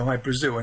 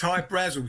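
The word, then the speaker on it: Hy-Brasil: High Brazil, people pronounce it High Brazil, and it's Brazil, okay. – yeah, yeah. But it's High Brazel because that's how the Irish would pronounce it, because it's an it's an island that's off the southwest coast of Ireland.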